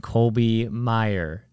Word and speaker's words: Colby Maier: And I'm here today with my friend Colby Maier. Is that, that's how you say it, right? – Yeah, I'll make sure because the spelling is different.